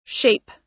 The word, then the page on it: shape: American English